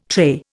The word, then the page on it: trait: Indian